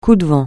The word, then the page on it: coup de vent: French